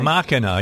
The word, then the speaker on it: Machina: Needless to say, this is a horror flick from the same guy that brought us Machina. Am I saying that right? – Yeah, Machina, yeah.